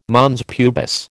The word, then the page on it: mons pubis: American English